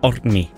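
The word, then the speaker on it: Árni: Hvad var det rigtige? Må jeg lige få den? – Ordni. Ja.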